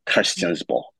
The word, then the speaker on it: Christiansborg: So then Mapungkai's, you know, son, Ukonkwe, right, was the one who granted the land that the Christiansborg, or to give it its fancy Danish pronunciation, Christiansborg, so gave permission to the Danes to build Christiansborg on the current site.